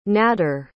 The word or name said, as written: nadder